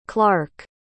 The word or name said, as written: clarke